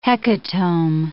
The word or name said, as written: hecatomb